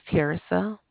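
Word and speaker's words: Tearsa: My name is Tirsa.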